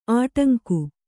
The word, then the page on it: ಅಡಂಕೆ: Kannada